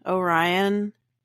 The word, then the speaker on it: Orion: My name is Haley Orion, like the constellation Orion.